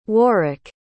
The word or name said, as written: warrick